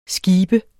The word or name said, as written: skib